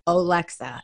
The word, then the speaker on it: Olexa: Okay, you guys, when I have somebody, and the way you spell her name is so simple, it's like when people look at Olexa, they go, how do I pronounce it? And I'm like, Olexa. And they go, it's just like it's spelled. And now I just did it.